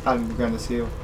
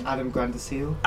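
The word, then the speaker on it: Adam Granduciel: Yeah, Adam Grandeseal. – Again, sorry? – Adam Grandeseal. – Adam Grundeseal. I've never known.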